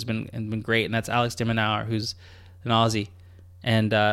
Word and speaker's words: de Minaur: Yeah, the last name is de Minaur.